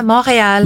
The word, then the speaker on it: Montreal: That's how you pronounce it, yeah? – Yeah, Montreal. – Montreal. – Montreal in English.